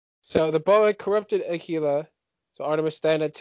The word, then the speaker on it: Akila: So unfortunately, Artemis had to help Diana, you know, kill Akalia. I just realized I'm saying her name wrong. I apologize. – I think Akalia. It's Akila. Is it Akila? Akalia? Akila? – A-K-I-L-A.